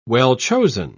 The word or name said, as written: well-chosen